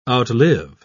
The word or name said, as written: outlive